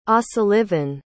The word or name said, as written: osullivan